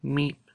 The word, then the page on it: mip: Lwaitel